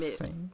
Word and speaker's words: Mx: It's still pronounced mix. It's like mix.